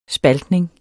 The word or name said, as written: spaltning